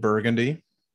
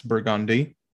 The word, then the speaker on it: Burgundy: Cause there's many of videos on, like we have a street named Burgundy, but that is not how it's pronounced. It's Burgundy.